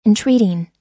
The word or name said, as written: entreating